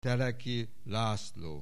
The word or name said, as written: Teleki László